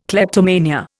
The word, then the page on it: kleptomania: Indian